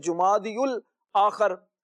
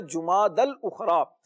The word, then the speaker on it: جماد الاخرا: اچھا, یہاں پر تلفظ بھی بعض لوگ درست نہیں کہتے, بعض کہتے ہیں جمادی الاخر, بعض کہتے ہیں جماد الاخر, تو یہ دونوں ہی تلفظ غلط ہیں. درست تلفظ ہے جماد الاخرا.